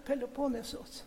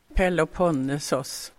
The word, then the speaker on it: Peloponnesos: Alltså det där är en liten bit utav fastlandet och Peloponnesos. – Jaha, nu hör det grekiska uttalet, va? Peloponnesos.